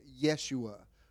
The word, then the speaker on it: Yeshua: The pronunciation would be like Yeshua, which was actually a form of Joshua. And it wasn't even spelled with a Y. It was like O-U-E-S-H-A in our vernacular.